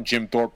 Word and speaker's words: Jim Thorpe: So anyways, Jim Thorpe.